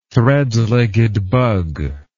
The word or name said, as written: threadlegged bug